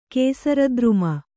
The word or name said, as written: ಕೇಸರದ್ರುಮ